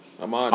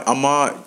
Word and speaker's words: Ahmaud: Ahmaud. – Ahmaud. Ahmaud.